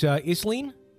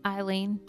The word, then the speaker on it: Isleen: Is this Isleen? – Eileen. – Eileen? That's how you spell Eileen? – No, that's a different way to spell Eileen. It's Eileen spelled like island with an I-S.